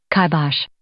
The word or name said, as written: kibosh